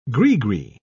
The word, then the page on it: grigri: British English